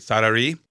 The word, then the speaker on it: Sarai: I want to make sure I'm saying this right. Sarai? – Sarai, yes.